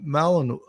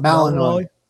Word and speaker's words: Malinois: a Belgian Malinois. Uh, I don't know how to pronounce it out. – Malinois.